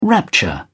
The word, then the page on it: rapture: British English